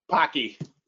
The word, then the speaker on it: Pocky: Pocky.